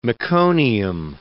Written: meconium

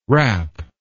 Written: rapp

